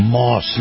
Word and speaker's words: Maus: This portion of the program brought to you by our friends over at Moss Nissan. Now, Moss is spelled M-A-U-S,